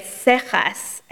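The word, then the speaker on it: Seixas: As part of the welcoming ceremonies for the President of the United States, Moses Mendez, and I never say it right, Sechas, I think is how you're supposed to say it, Sechas, this is why Cantor Barrick with her Sephardic roots is here.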